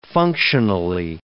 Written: functionally

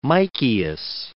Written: Micheas

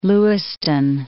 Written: Lewiston

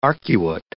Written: arcuate

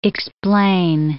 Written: explain

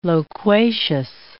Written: loquacious

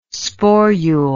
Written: sporule

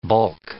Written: bulk

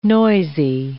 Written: noisy

